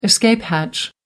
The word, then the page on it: escape hatch: American English